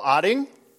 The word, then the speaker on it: Oetting: We have Will Oetting.